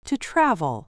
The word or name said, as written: to travel